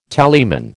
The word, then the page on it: tallyman: American English